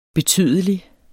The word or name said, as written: betydelig